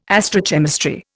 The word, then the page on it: astrochemistry: Indian